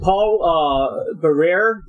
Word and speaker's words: Paul Barrere: And you have, is it Paul Barrere? Is that how you pronounce him? – Yeah.